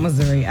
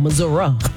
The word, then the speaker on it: Missouri: Missouri, eyes have been on Missouri. I was like, oh, no. – You saying it wrong. Missouri.